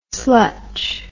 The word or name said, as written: slutch